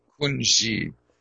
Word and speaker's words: Kunji: Kunji. That's how that's pronounced. That's a Tibetan word that means all. Kun, it means all, and then ji means ground.